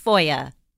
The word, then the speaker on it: FOIA: It's that it's pronounced FOIA.